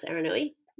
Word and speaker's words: Aranui: First they were just on Eureka Street in Aranui. Is that right? – Yep, that's the way I would pronounce Aranui.